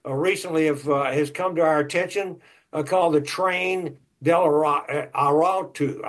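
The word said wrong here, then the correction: Tren de Aragua: I think that's the way you pronounce it. Train del Aragua. That's what it is.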